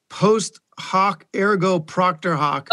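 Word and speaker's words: post hoc ergo propter hoc: I think it's post hoc ergo proctor hoc.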